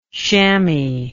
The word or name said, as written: shamoy